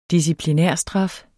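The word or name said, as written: DISCIPLINÆRSTRAF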